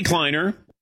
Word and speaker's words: Kleiner: Z Kleiner rhymes with recline.